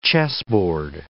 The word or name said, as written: chessboard